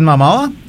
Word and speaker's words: Momoa: I'm going to say Jason Momoa. Is that how you say it? – Yeah, Jason Momoa.